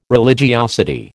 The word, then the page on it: religiosity: American English